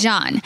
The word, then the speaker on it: Jean: Is it John? – It's John. – You okay. Sure? – Yes, it's John. – It's John. – Nobody says Jean. – It's J-E-A-N.